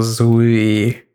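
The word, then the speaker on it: Zooey: Эмоджи «Рыжая женщина», если Эмили круче, и черноволосая женщина, если Зуи круче, если ты настаиваешь Конечно, так произносить. Два О у нее в имени. – Да хорошо, я, я не спорю, просто я только от тебя услышал Зуи.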